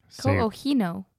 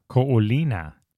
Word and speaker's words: Ko'olina: How do you say that? Ko'ohino. – Ko'olina.